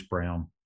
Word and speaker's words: Braun: Even, like, their rookie, Christian, well, it's spelt Braun, but it's pronounced Brown, apparently.